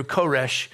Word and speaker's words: Koresh: It's actually pronounced in Hebrew Koresh.